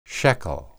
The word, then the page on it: sheqel: hebrew